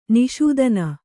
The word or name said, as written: ನಿಷೂದನ